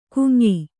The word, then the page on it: ಕುಂಯ್: kuy`; Kannada